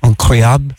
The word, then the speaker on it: incroyable: We have incroyable, is that how you say it? Yes.